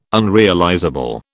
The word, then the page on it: unrealizable: British English